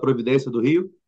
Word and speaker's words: Providência: Am I pronouncing it at all right? – Halfway there. Morro da Well, you say it now. – Providência do Rio.